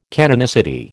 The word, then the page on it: canonicity: American English